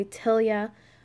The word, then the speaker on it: Tealia: So my dad added the E for T-E-A-L-I-A. They pronounce it a bit differently. They say Telia.